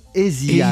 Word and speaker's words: Aja: Ah, en fait, Donald Fagan, il prononce Asia, Asia lorsqu'il parle de cet album.